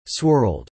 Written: swirled

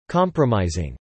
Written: compromising

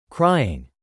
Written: crying

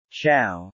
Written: chow

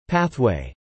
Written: pathway

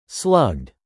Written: slugged

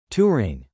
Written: touring

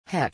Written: heck

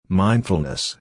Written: mindfulness

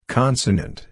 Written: consonant

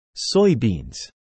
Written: soybeans

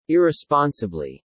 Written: irresponsibly

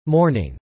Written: mourning

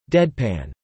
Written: deadpan

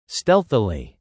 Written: stealthily

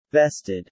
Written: vested

